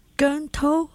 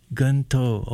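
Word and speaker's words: Keung To: Gun To. – Gun To, okay. And I think, I'm still trying to say it right, but Gun To, that's spelled K-E-U-N-G.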